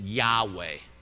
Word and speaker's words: Yahweh: YHWH is how we would spell it in English, and the way it's pronounced is Yahweh.